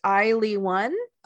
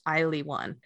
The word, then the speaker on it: ILY:1: And I didn't even say the group, but it is pronounced Ily One. It's written like ILY:1. So like I love you, :1, but it's pronounced Ily One.